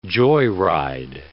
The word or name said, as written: joyride